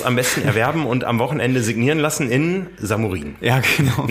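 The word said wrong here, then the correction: Šamorín: Nee, Chamorin spricht man es, glaube ich.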